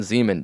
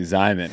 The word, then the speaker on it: Zyman: Yeah, or Ryman Zeman, depending on how you pronounce it. – It's definitely Zyman, because it rhymes with... – Ryman.